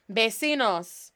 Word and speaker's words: vecinos: Mis, M-I-S, amigos, A-M-I-G-O-S. Mis amigos, mis vecinos. Vecinos, V, remember V's are pronounced like a B, it's not vecino, vecinos, V, as in my daddy's name, Vicente, Vincent, E-C-I-N-O-S, por mis vecinos.